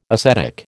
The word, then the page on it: acetic: American English